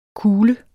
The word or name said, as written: kule